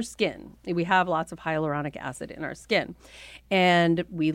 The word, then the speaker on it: hyaluronic acid: I want to know in particular about hyaluronic acid.